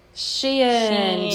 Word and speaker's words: Shein: Shein, or how do you pronounce it? Is it Shein or Sheen? – Shein. Yes.